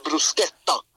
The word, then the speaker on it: bruschetta: Ja, just det, och där har vi den där H-grejen. Det blir bruschetta. – Okej. – Det är K.